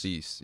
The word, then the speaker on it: Thees: Mark Thies. – Mark Thies? – Thies, yeah. – Spell that last name. – T-H-E-E-S.